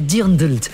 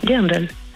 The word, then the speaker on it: Dirndl: Et chez les filles, c'est le Dirndl. Et je vous promets que c'est comme ça qu'on dit. Hein. Ça s'écrit D-I-R-N. NDL. Regardez, c'est ce que me dit mon ordinateur. – NDL. Ah oui, Dirndl.